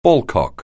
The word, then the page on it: ballcock: anglais